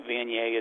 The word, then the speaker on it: Viognier: Viognier. That's how I remember how to say it. I actually have to spell out yay at the end, so Viognier. So Viognier, yes.